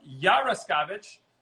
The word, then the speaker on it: Yarascavitch: We're on live with Emily Yarascavitch. Did I pronounce that right? – Nailed it. – Yes!